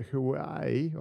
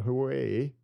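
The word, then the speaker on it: Huawei: These are two Chinese telecom firms, and I guess a lot of us probably haven't heard too much about them, but Hawaii, or Hawaii, depending on how you pronounce it,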